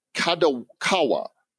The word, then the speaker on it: Kadokawa: Dentsu and Kadokawa.